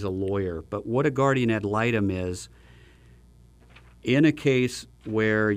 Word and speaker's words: guardian ad litem: Guardian ad litem? – Close. Guardian ad litem.